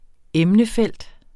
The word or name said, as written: emnefelt